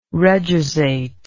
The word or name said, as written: reduzate